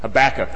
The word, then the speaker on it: Habakkuk: Habakkuk? Habakkuk? How did you say it? Habakkuk. That's what it said in the pronunciation guide in the lectionary.